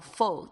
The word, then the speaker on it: full: full 这个字，这个 full 跟那个秋天那个 fall 是不一样的发音，但是呢嘴巴稍微小一点，但是也不是完全是扁平的状况，